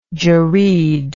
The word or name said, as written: jereed